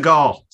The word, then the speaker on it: Segal: Segal. Fucking, like Steven Segal.